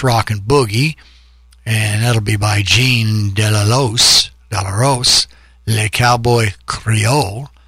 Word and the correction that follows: Delafosse: Am I saying his name right? Delafosse, D-E-L-A-F-O-S-E, French rock and boogie.